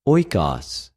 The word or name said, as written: οἰκος